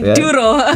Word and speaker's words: Duro: We're going to play a track called Duro, if I got it correct. – Yes, you're pronouncing it absolutely Duro.